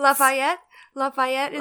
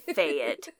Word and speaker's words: Lafayette: Why do you say it so bad? It hurts. – Lafayette, is that better? – Yes, that's so much better. Lafayette. You're putting the wrong emphasis on the wrong syllable.